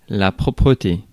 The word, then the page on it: propreté: [pʁɔ.pʁǝ.te]